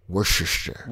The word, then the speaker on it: Worcester: Worcester. – That's how you say it.